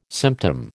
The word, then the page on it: symptom: American English